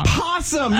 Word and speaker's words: opossum: Opossum, you guys. It's called an opossum. The O is not silent.